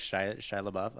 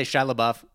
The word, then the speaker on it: Shia LaBeouf: Shia LaBeouf. I just say Shia LaBeouf.